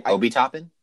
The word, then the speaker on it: Obi Toppin: Yeah, I think I just get mixed up because the O's are pronounced differently, but I want to pronounce him the same. So whenever I pronounce his name – Obi-Wan Kenobi and Toppin.